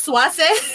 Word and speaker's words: Swayze: Apparently, His name in Spanish is pronounced Suase.